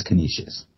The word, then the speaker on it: Canisius: I think it's just Canisius. – Canisius? Okay. – Chris Bevins, our sports editor, kind of pronounced it for me when we were talking about it. It's Canisius.